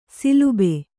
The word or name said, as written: ಶಲಭ